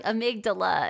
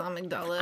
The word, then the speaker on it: amygdala: But I was just waiting for her to say amygdala and she was like amygdala. And I was like, no, because like just the emphasis is on the wrong syllable than what we're used to.